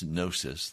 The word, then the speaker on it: gnosis: Well, as we shared earlier, and I need to review quickly for you, some people say gnosis, but really in the Greek it's gnosis. The G is silent.